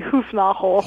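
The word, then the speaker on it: Hoefnagel: The Dutch artist names are a real chore, but it would be Hufnagel if you were doing it right. – The Dutch way. – You don't give a hard G.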